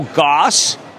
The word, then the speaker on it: Gass: By the way, Michelle Goss, which is how you pronounce G-A-S-S, the CEO, just now said some things about tariffs on Kohl's.